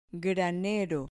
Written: granero

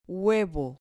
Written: huevo

